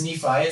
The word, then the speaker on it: Nephi: but it is Nephi.